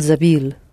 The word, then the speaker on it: забыл: Он забыл. – Mm-hmm. Pronounce it hard, please. Забыл. – Он забыл. – Yes. What is the middle vowel after б? – Ü. – Yes, Ü. It's that one.